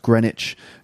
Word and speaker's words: Greenwich: It's actually pronounced Greenwich.